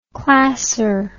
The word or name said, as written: classer